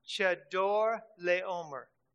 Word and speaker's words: Chedorlaomer: Chedorlaomer.